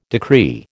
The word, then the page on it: decree: American English